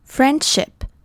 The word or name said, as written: friendship